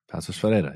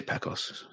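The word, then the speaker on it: Paços Ferreira: Passos Ferreira, yeah. – I say Pacos. It's just easier.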